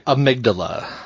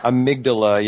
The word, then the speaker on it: Amygdala: Is it – how do you say it? Amalga? What do you say? – Amygdala. – Amygdala, yeah. Amygdala.